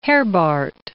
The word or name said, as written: Herbart